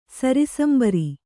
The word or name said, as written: ಸರಿಸಂಬರಿ